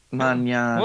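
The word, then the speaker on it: Mañana: How do you spell Manana? – M-A-N with the tilde over the top. – Squiggly thing. – Manana. – Well, then, hey, you know what? Then I pronounce it properly.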